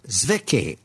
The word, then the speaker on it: Zwicky: And now we go to a Swiss astronomer, Zwicky, I believe is the correct way to say it.